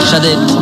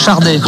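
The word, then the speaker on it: Sade: Par contre, ça se prononce Chardé, avec un R qui n'existe pas, mais en prenant, on dit Chardé normalement. Et je crois que d'ailleurs, elle le dit, oui, elle le dit elle-même, Chardé.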